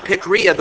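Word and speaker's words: pikria (πικρία): You see, bitterness comes from the Greek word pikria. Okay, you can check it out later, but that's how I pronounce it, pikria, the word meaning acrid, which means irritating, corrosive, or eating away.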